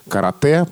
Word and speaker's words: карате: «Карате» произносится твердо, но пишется «е».